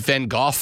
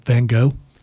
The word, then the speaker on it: Van Gogh: Just a couple of months away, there is a big Vincent Van Gogh exhibition that's coming to Greenville.